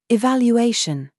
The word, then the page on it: YOU: British English